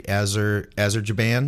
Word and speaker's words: Azerbaijan: On top of that, it says the largest fine recorded was $500,000 paid by is this an African country, Azerbaijan? Am I saying that right, Dave? – You're saying it right.